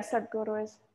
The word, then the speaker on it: Sadhguru: The first one that came to mind is Sadhguru. – Okay. – If I'm Why? – Pronouncing it correctly. – Yeah, Sadhguru is good.